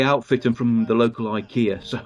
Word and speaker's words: IKEA: Okay, IKEA. Watch and be amazed. – Which to me always sounds Japanese. – It really does. – But I am assured by Swedish people that's how it's pronounced.